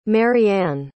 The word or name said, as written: maryann